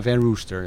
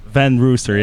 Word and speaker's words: Van Rooster: Hoe moet je het uitspreken? Van Rooster en wij zeggen over het algemeen Van Rooster, ja. – Van Rooster, ja.